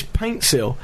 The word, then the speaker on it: Paintsil: Pansil's not even his name. His name is Paintsil.